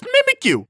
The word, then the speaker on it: Mimikyu: Who pronounces it Mimikyu? It's just Mimikyu, because it's mimicking it.